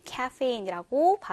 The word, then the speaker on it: caffeine: 영어 발음으로는 caffeine이라고 발음을 하는데요.